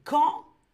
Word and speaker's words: campagne: Est-ce que je peux prononcer, bien sûr, campagne C'est campagne, c'est ça qui est difficile, campagne.